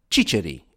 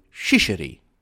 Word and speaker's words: ciceri: which are cicci in Italian, but ciceri in Sicilian. Supposedly, the French could not pronounce this word and would come out with shisheri, sealing their own doom.